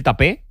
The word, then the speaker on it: Tapé: que es como creo que se pronuncia, porque el día que lo ficharon yo me vi algunos partidos para no solo para ver cómo jugaba, sino también para cómo el comentarista en cuestión pronunciaba su nombre. Eran partidos del Paris Saint-Germain y el comentarista francés de su misma nacionalidad decía que era Tapé, así que nos quedamos con Tapé, salvo que él diga lo contrario.